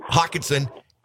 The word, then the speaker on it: Hockenson: You know, I got to tell you, I've always, I hated when the Lions had the tight end, Hockenstein. How do you pronounce his last name? – Hockenson. – Hockenson.